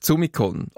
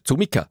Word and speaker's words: Zumikon: Nach ihr geht's in die Provinz, auf Zumikon, oder Zumika, wenn ich es jetzt original zürcherisch aussprechen möchte.